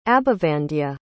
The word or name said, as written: abhivandya